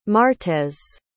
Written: martez

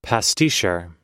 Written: pasticheur

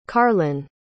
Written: carlyn

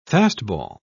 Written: fastball

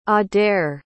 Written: adaire

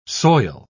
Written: soil

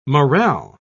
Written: morale

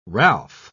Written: Ralph

